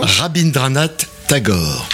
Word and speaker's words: Rabindranath Tagore: Rabindranath Tagore.